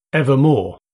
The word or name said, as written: evermore